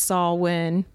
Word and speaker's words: Samhain: And he was like, I know it's pronounced Samhain, but it's spelled like Samhain.